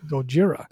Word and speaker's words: Gojira: Gojira.